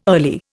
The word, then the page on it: early: Indian